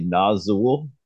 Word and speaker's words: Nazxul: I say Nazul. It's spelled NAZXUL, and they formed in the early 90s. Again, Steve Hughes from Slaughter Lord. He started or helped start Nazul.